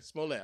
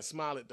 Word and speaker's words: Smollett: I don't know if I'm pronouncing that dude's name. – Smollett? – Oh, okay. Smollett. I've been saying Smollett the whole time. I'm fucking that man's name up.